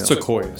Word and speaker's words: sequoia: And we head out to this national park, and there's some big reds and soya trees, and am I saying it right? Soya? – Sequoia. – Sequoia.